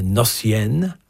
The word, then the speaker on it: Gnossienne: Here's one of his created genres, the Gnossienne. That's with the silent G, in case you're wondering.